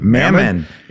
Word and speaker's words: Mammon: It's money, and he gave it a name, Mammon. Can I say that right? – Mammon.